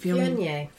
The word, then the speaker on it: Viognier: Viognier? How do you say it? – Viognier.